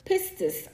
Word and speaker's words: pistis: The New Testament word faith comes from the Greek word pistis, and that's spelled P-I-S-T-I-S, which simply means belief or confidence, right?